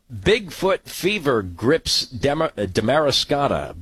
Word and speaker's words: Damariscotta: Damariscotta.